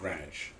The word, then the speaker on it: Greenwich: Well, the one in Connecticut is pronounced Greenwich. – Yeah. – It's spelled Greenwich, but right, it's Greenwich.